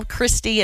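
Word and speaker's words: Cristy: Yeah, just call, email Christy, and it's spelled weird.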